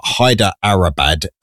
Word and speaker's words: Hyderabad: It's like Hydra, but Hyderabad, I think, is how it's pronounced.